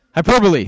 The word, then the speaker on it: hyperbole: Hyperbola. He's hyperbole. Think hyperbola is the math thing, right? Hyperbole.